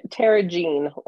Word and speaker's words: Tara Jean: Tara Jean, like Tara, planet, and Jean, like genome.